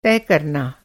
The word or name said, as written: तय